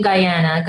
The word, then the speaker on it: Guyana: I was born in Guyana.